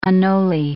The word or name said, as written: anole